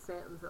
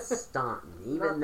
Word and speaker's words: Staunton: And it is Stanton, even though it's spelled Stanton. – Yes. – The U is silent. – It's not Staunton, though that's what you want to say.